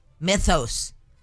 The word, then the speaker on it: Mythos: Pronounce the word myth. Mythos. Whatever! Mythos. All right, Claude Mythos. It's been released. – Myth? – Mythos. – Mythos. – Yeah, it's M-Y-T-H-O-S.